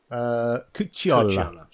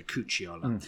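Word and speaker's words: Cucciolla: Forgive my pronunciation. Cucolia. – Cucciola. – Sorry, oh, it's a double C. Sorry, Cucciola. Which he had a very long and sort of stable career, but he did a lot of dubbing work for Anglophonic films.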